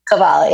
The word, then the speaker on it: Kabbalah: Kabbalah, yes.